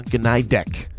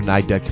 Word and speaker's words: Gnidek: An amazing young singer-songwriter by the name of Christina Gnidek, or Gnidek if the G is silent.